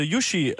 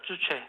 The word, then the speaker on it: Juche: The Yushi, is that right? Yushi, is that how you pronounce it? – What does the name Yushi? – Yushi. – Yuchi. – Yuchi. – J-U-C-H-E. That is the ideology in North Korea. Yes. – Juche. – You understand now? – Yeah.